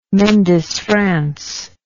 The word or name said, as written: mendes france